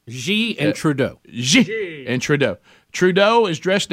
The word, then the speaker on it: Xi: Xi, is that how you say his name? Xi? – Xi.